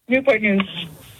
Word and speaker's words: Newport News: Newport News.